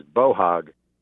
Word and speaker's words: boar hog: Boar hog, pronounced in the South, is bohog.